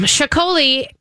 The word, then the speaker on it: Txakoli: Chacoli, which is spelled T-X-A-K-O-L-I, in case you're wondering and see it and try to figure that one out, is the Basque wine that has got a little bit of sparkle to it.